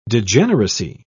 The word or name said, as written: degeneracy